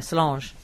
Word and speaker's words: Solange: Yeah, Solange.